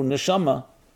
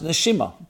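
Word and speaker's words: neshama (נשמה): As a matter of fact, the word soul in Hebrew, neshama, can also be pronounced neshima, breath.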